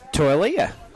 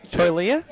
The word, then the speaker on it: Taulia: It's Taulia. – Taulia,